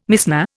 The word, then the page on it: Mishnah: Indian